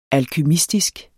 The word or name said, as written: alkymistisk